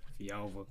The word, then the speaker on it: Villalva: Like I was saying, Tyrell Villalva, SUNY Oswego. – Wait, well, what's your last name? – Villalva. – Villalva? – Yeah.